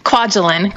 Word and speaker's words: Kwajalein: Kwajalein.